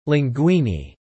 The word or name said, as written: linguini